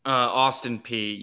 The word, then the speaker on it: Austin Peay: Isn't Austin Peay as you pronounce it? Because I know that's a Tennessee school. Is that right? – Austin Peay, yes.